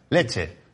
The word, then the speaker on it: Lecce: Y, y Lecce, o ¿cómo se diría? Leche, ¿no? ¿Cómo? Lecce, ¿cómo se dice la, ciudad? Leche.